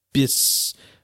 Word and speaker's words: Bucyrus: I'm saying Bucyness, Missouri, Bucyness. – It's not an N, that's an R. Bucyrus. – Oh, it's an R? – Bucyrus, I think that's you're right. – Bucyrus, Missouri.